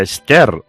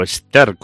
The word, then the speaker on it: Esther: Bueno, pues hoy he felicitado a mi amigo Aarón, porque hoy es San Aarón, también es Santa Esther, o Esther, con H o sin H, que yo las pronuncio igual.